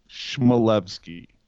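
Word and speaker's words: Chmelevski: I literally asked the guy, how do you pronounce your name? And he said, Chmielewski. It's not four syllables. It's three. Chmielewski.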